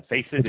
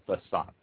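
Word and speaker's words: façade: it's not a facet, it's a facade.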